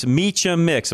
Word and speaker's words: Miche Mix: Adrian is with us, Viesca, and CEO of, make sure I say this right, it's Miche Mix. Am I saying that right, Adrian? – Yeah, that's perfect. You got it.